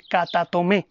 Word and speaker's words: katatomé: La palabra circuncisión en los manuscritos antiguos vienen de la palabra griega koiné katatomé, acento en la última E, katatomé.